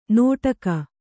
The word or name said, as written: ನೋಟಗ